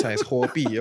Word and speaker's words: Huobi: Huobi, yes. – Huobi, sorry. – You are a Chinese person. – That's right. – Sorry, please continue. – My pronunciations mean great. – It's fine. It's Huobi.